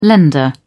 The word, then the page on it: lender: British English